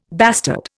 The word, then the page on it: bastard: Indian